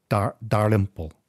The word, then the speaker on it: Dalrymple: So William Dalrymple, who actually I think pronounces his name Dalrymple, sounds a bit more Indian the way he pronounces it.